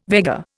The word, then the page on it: Vega: Indian